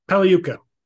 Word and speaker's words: Pagliuca: Pagliuca. – Pagliuca.